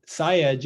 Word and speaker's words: Sayegh: And then Sayegh is S as in Sam, A Y E G H.